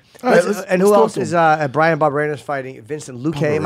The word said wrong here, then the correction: Vicente Luque: Vicente Luque, yeah.